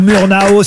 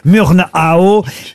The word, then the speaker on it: Murnau: C'est Murnau ou Murnau, si vous voulez le prononcer effectivement en français ou à l'américaine, mais au départ c'est Friedrich Wilhelm Murnau, FW Murnau.